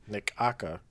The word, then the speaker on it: Necaxa: In terms of their lineups, Nikaka? Like their... What? – Necaxa. – Necaxa? Is that how you pronounce it? – I think so.